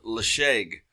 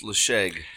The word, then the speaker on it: Ledaig: We have a 10-year-old single malt Scotch whiskey called Leshaig. Not spelled Leshaig, though. It is spelled L-E-D-A-I-G, but pronounced Leshaig.